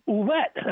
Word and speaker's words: Ouellette: Yeah, and oh, by the way, Mr. Ouellette, am I saying it right? – Yeah, R.J.